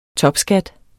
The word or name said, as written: topskat